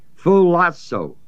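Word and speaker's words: Phulasso (φυλάσσω): The word keep, we're speaking here now of Gentiles, you know, is the Greek word spelled P-H-U-L-A-S-S long O. If the uncircumcision keep, really try to care to be righteous. If they really try, that's this word Phulasso.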